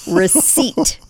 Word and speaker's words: receipt: Receipt. RECEIPT. Yes, there's a silent P.